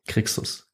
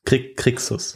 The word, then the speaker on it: Crixus: Crixus. – Crixus.